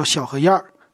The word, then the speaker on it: 小河沿: 它的读音哈叫小河燕小河燕，它写怎么写呢？它是河沿的沿字啊。